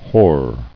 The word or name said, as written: Whored